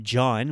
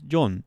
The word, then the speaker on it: John: My name is not John when I speak Japanese. It's John. It's a slight variation.